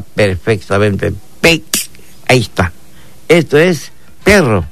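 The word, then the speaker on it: peek': En lengua maya lo pronunciamos de esta manera. Pec. Pec. Pec. Así como suena. Pec. Sin forzarnos.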